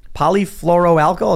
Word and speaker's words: polyfluoroalkyl: They say polyfluoroalkyl, is that how you say it? – Yeah.